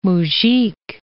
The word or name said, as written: mujik